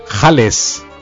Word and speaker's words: jales: Jales. – En singular jale, del hebreo jalot jalá, en pronunciación yiddish.